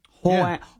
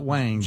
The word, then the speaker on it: Huang: Excuse me? – Huang. – Not Wang. – Jensen Huang. – Jensen Huang, yeah.